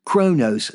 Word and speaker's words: chronos: But the correct way to pronounce chronos is chronos, C-H-R-O-N-O-S, chronos. Or, chronos, right, chronos. Think of the blackbird crows. Okay, crows, chronos.